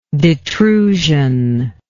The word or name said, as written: detrusive